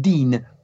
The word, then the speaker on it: Digne: Yes, and it's pronounced Dean. They get it right, funnily enough. They don't get Kante right, but they get Dean right. But it's actually Digne.